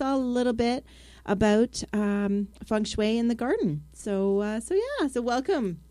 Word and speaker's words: feng shui: Okay, feng shui, pronounced feng shui, was developed over 4,000 years ago in China.